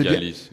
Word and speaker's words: Dialis: C'est Dialis. – C'est bien comme ça que ça se prononce. – Dialis. – Ouais, c'est ça.